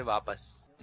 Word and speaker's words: واپس: درست تلفظ بتانا ہے، واپس یا واپس؟ – واپس. – آپ نے جواب دیا واپس. اس طرح آپ کا جواب ہو گیا غلط. صحیح تلفظ ہے واپس.